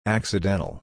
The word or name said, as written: accidental